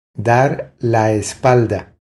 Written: Dar la espalda

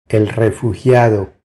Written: El refugiado

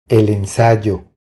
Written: El ensayo

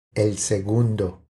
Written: El segundo

